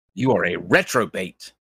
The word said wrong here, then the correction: reprobate: I also mispronounce it. It's reprobate. R E P. I say retrobate. – Wait, I said retrobate. That's like a what is that? That's like a throwback. Reprobate.